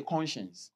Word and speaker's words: conscience: Everybody say conscience. – Conscience. – You can spell it like con and science.